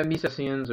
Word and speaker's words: Misesians: Misesians, did I say that right? – Yeah, Misesians or Misesians. Good enough. – I always get that I wrong.